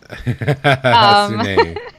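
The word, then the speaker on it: Hassouné: We are going to be talking to Jade Hasune. I hope that's how you say it. – That's how I was going to say it. – Hasune.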